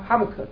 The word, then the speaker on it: Habakkuk: Habakkuk is how it's pronounced.